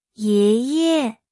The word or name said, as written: 爷爷